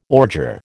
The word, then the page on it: ordure: American English